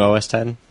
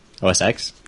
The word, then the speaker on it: OS X: The new OS 10. OS X? – Yeah, the new OS 10. – OS X? – No, that's not pronounced X. – No, but it's an X.